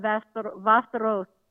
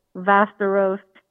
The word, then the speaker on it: Västerås: It's a little town called Västerås. That's how you say it. Västerås.